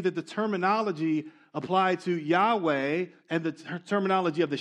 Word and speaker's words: Yahweh: But some think you say it Jehovah. But most modern scholars agree it was probably pronounced Yahweh.